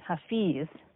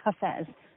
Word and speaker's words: Hafez: American English tends to pronounce his name Hafiz because I guess we understand the I pronounces our American eyes, but it's actually Hafez.